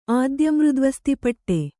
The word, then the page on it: ಆದ್ಯಮೃದ್ವಸ್ತಿಪಟ್ಟೆ: ādya mřdvasti paṭṭe